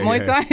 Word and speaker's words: Muay Thai: Yeah, Muay Thai? – Yeah.